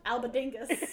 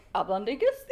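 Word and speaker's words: albóndigas: I'm so upset that not once in the year that I worked at a tapas restaurant that no one pronounced it albondigas.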